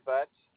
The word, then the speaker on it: Butz: Last name is spelled B-U-T-Z, Edward Butts.